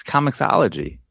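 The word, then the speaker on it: Comixology: Why are all you guys not pronouncing the X? It's Comicology. You don't, the X is not silent. You guys drive me nuts.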